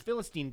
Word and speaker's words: Philistine: Oh, they say Philistine wrong in the movie. Every time... – Philistine? – It's like Philist... Like they put Philistine. The wrong emphasis on the wrong syllable.